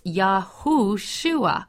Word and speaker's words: Yahushua: That is why I am so adamant that we must call our Messiah Yahushua, because that is how you say Joshua in ancient Hebrew. It's Yahushua.